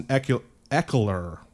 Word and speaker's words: Ekeler: Uh, Austin Ekeler, is how you say that name, ankle limited so watch his avail- availability for Thursday.